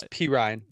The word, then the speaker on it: Perine: That's Perine. I said it wrong, actually. – It's kind of gross. – It sounds like orange rind.